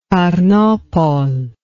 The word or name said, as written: tarnopol